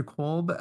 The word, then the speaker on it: Kolb: So my name is Andrew Kolb, which is spelled K-O-L-B, like Bravo.